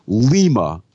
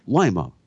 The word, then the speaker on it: Lima: Within 30 seconds of Wednesday's Michigan-Iowa State basketball game, first play-by-play man, John Chombi, referred to Lima, Ohio, instead of Lima, Ohio, which is how it's pronounced.